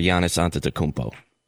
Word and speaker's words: Antetokounmpo: So we have Nikola Jokic, Joel Embiid, or Giannis Antetokounmpo.